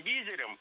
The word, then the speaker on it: визирь: Визирь – неверно. Визирем – тоже неверно. Правильно – визирь. Ударение ставим на второй слог и сохраняем его при склонении. Визирем, визиря, визирю.